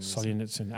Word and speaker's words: Solzhenitsyn: Solzhenitsyn.